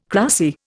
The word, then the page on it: cracy: Indian